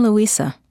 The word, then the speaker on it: Luisa: I'm Luisa. – Luisa? How do you spell that? – L-U-I-S-A.